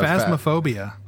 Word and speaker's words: Phasmophobia: Phasma, uh, phasmophobia.